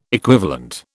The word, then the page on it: equivalent: British English